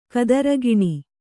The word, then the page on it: ಕದಿರುಗಿಣಿ: kadirugiṇi